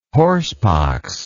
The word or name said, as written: horsepox